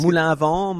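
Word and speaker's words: Moulin-à-Vent: The main ones I think of are things like Morgon, Moulin Avant, and is that Moulin Avant? Is that how you say it? – Moulin Avant, Morgon, yes.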